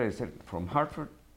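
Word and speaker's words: Hertford: Pero la ortografía de ese Hartford es con E en Inglaterra.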